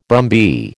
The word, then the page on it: brumby: American English